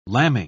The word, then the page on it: lambing: British English